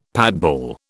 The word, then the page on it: patball: British English